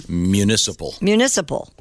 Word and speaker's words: municipal: Municipal. – Even when you say it right now, it sounds like you're putting two N's in it.